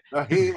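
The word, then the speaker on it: Nyheim: Naheem Hines. – There you go. It looks like Nehemiah almost on paper.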